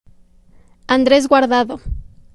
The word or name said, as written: Andres Guardado